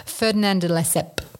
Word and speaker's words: Ferdinand de Lesseps: Can you pronounce the name for me? – Ferdinand de Lesseps. – Ferdinand de Lesseps.